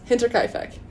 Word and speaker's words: Hinterkaifeck: Hinter. – Hinter. – Kai. – Kai. – Feck. – Feck. – Yes. – Hinterkaifeck. – Hinterkaifeck. To my knowledge, that is how it is said.